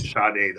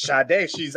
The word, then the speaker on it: Sade: It's actually pronounced Sade, though. – That's Sade. I'm Nigerian? I think that's how they say it.